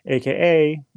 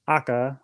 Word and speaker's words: Aka: And AKA means Aka, or it's pronounced Aka in Japanese. That means red, and Kyosho is red.